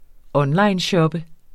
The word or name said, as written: onlineshoppe